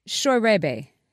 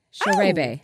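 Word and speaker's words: Scheurebe: E-B-E. S-C-H-E-U-R-E-B-E. How would you say that? – I would say, and I don't know, Schwerebe. – Closer than I expected. It's Schwerebe. Schwerebe.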